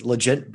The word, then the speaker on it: Legent: So this is Legent Bourbon. So it's like legend, kind of L-E-G-E-N-T, Legent, or Legent, how you pronounce it.